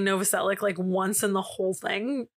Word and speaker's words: Novoselic: And his last name is Novacek. Isn't that what it is? Novacek. Fuck. – Sure. – Of course, I can't remember his name in this. Novacek. Novoselic. I can't pronounce it right ever. Novoselic.